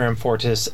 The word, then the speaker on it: Amfortas: So that's how you pronounce that. – Yes. – Awesome. – He learns that Dr. Amfortas attends that church and was at the service where the priest was killed.